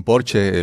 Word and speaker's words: Porsche: no sé cómo se dice en México, Porsche, le dicen Porsche, el Porsche, Porsche, Y él se compró este vehículo deportivo porque, porque sentía que se lo merecía.